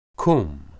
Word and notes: qum: Hebrew

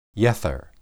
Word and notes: yether: Hebrew

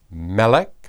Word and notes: melek: Hebrew

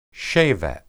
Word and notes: shebet: hebrew

